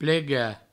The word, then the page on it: plegar: plega